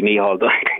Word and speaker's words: Micheál: Stephen and Michael, Micheál, he beat me for that. – Oh, sorry, apologies. – Micheál, yeah, the Irish pronunciation.